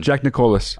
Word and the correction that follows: Nicklaus: Is that how you pronounce it, really? – No, Jack Nicklaus. – That's what I said. – You said Jack Nicholas. – Oh. Jack Nicholas, I said. – No, there's no vowel.